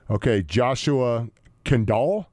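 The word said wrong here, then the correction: Kaindoh: Is that how you say it? – Kendo.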